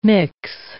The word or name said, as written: mix